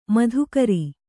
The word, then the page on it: ಮಾಧುಕರಿ: Kannada